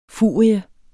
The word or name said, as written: furie